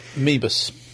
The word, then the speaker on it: Moebius: And so has Mobius. Or Moebius, or however the French pronounce it. – Mebus. – Mebus.